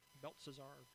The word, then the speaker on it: Belshazzar: Belshazzar.